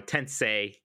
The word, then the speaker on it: Tensei: I said it wrong. Tensei.